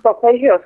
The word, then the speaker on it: solfeggio: Is it the solfeggio scale? – Solfeggio scale, yes, yeah.